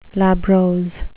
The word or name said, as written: labrose